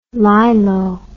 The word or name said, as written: lilo